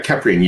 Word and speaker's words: Caprian: Yeah, Caprian, you've got it correct. And a lot of people pronounce it wrong. And Egyptologists have even said that I might be pronouncing it wrong. So it's all good. We'll go with Caprian, and that's going to be our common pronunciation tonight. – Okay, and so how long is the Caprian vampire tradition?